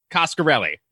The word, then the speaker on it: Coscarelli: Don Coscarelli.